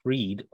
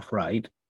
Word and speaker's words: Fried: But, composer, Connecticut resident Gerald Freed, or Fried, depending on how you say it, died at 95.